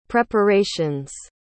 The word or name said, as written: preparations